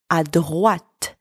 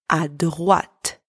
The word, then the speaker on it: à droite: There is a difference between tout droit, which means straight ahead, that we used in our first expression, where the final T is silent, and à droite, where you hear the T. It's not silent. Tout droit, à droite. Tout droit, T silent, à droite, T pronounced.